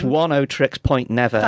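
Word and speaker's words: Oneohtrix Point Never: It's 10 Trix Point Never.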